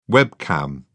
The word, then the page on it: webcam: British English